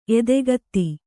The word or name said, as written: ಎದೆಗತ್ತಿ